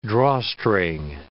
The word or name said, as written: drawstring